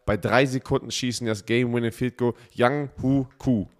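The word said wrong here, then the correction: Younghoe Koo: La Yang Wei Ku. Ach, so wird er ausgesprochen.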